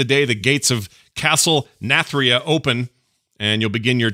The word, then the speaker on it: Nathria: Nathria.